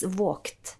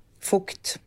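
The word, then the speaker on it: Vogt: Och så såg jag det med han där Nils Vågt. Är det han heter? – Fukt. – Heter han Fukt? Mm. – Uttalas det skrivs Vågt, men ja. Uttalas Fukt.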